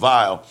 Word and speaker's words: Weill: And he collaborated with a composer named Kurt Weill, German, W-E-I-L, but it's pronounced Weill.